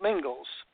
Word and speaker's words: Mingils: my last name is spelt Mingils, M I N G I L S.